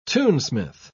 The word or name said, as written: tunesmith